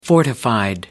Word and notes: fortified: British English

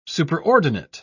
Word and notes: superordinate: British English

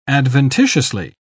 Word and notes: adventitiously: British English

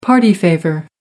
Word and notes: party favor: American English

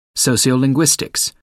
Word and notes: sociolinguistics: American English

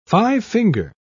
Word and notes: five-finger: British English

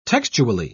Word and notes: textually: British English

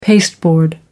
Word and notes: pasteboard: American English

